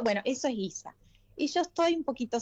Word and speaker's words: Giza: Giza, como Giza. Si fuera, en vez de, como si le pusiéramos una U y una I, como de guiso, pero Giza. – Claro, exacto.